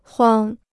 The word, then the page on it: 慌: huāng